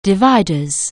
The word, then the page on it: dividers: anglais